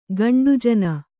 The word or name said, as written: ಗಂಡುಜನ